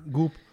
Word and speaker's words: Goop: Goop. – It rhymes with poop.